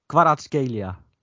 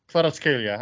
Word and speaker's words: Kvaratskhelia: probeer het eens. kwarad Kvaratskhelia. – Ja, Kvaratskhelia. – Kvaratskhelia, hij was in de Ja, nee, prima. Ik moet zeggen, voor de eerste keer echt prima uitgesproken.